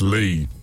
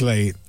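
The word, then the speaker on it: Leigh: But, it's Lee. – Yeah. – It's Lee. – Lee.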